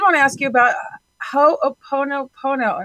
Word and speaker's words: Ho'oponopono: Oh, I love all these people who are so into Ho'oponopono, and you did say it correctly.